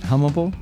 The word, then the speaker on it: hummable: Yeah, H-U-M-M-A-B-L-E. How would you say that? Hummable? Hummable. There it is.